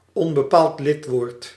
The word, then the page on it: onbepaald lidwoord: /ˌɔn.bə.ˈpaːlt ˈlɪt.woːrt/